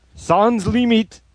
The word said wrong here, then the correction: sans limite: Actually, see, I messed it up. It's sans limite. – Yeah, you don't pronounce the S.